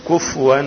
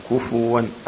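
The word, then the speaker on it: كُفُوًا: بعض الإخوة ينطقون كفوا بتسكين الفاء، والصواب ضمها كفوا، أي نعم تأخذ بالك، ولم يكن له كفوا، كفوا مش كفوا، ليس بتسكين الفاء ولكن بضم الايه؟ ضم.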